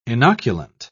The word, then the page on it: inoculant: British English